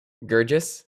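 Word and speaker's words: Guirgis: Gurgis? – Yes.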